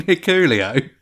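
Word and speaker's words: Hikuleo: Hicklu? I don't know. I kind of want it to do that, you know, the speech. – Go on then. You mean Hiculio? – Yeah, that's how you pronounce it.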